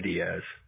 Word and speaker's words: Diaz: My name is Evan Diaz. – How do you spell your last name? – D-I-A-Z. First name E-V-A-N, and then last name D-I-A-Z.